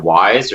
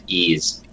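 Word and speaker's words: Ys: Is it Wise or is it Ease? – Ease. – Wise? Ease? Ease, yep.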